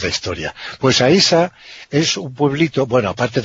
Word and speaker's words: Aisha: En árabe se pronuncia Aisha.